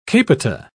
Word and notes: capita: British English

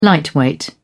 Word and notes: lightweight: British English

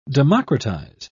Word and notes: democratise: British English

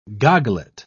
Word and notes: goglet: British English

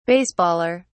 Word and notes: baseballer: British English

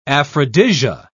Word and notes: aphrodisia: British English

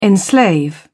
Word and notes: enslave: British English

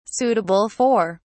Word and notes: suitable for: British English

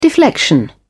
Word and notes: deflection: British English

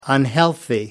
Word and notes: unhealthy: American English

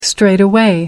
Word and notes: straightaway: American English

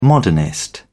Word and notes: modernist: British English

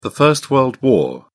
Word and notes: first world war: British English

